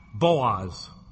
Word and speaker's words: Boaz: And Solomon begat Boaz, B-O-O-Z. Now, if you've read the book of Ruth, you know who B-O-O-Z is. In the Old Testament, it's spelled B-O-A-Z. It's Boaz.